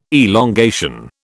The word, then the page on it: elongation: British English